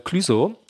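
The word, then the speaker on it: Clueso: Und auf jeden Fall gibt es diesen Song von Clüso.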